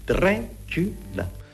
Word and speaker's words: Dracula: Three syllables. Dracula.